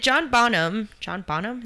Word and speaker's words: Bonham: Grohl cited John Bonham, is that how you say it? – Yes.